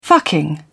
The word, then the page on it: fucking: British English